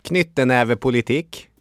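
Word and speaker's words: knyttnevepolitikk: Knyttenävepolitik. – Ja, det är klart att så uttalas det väl mer om man ska prata norska.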